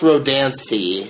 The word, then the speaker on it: Rodanthe: And it's pronounced Rodanthe. – Rodanthe. – So, thee. Thee. You could go something with that, with a thee sound.